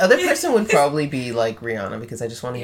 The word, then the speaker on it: Rihanna: Her name isn't Rihanna. It's Rihanna.